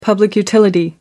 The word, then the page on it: public utility: American English